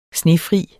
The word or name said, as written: snefri